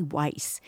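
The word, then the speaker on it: Wajc: And for full disclosure, Marty's name is Marty Weiss. And if you think you know how to spell it, you're wrong, because it's W-A-J-C, right? – Well, yeah.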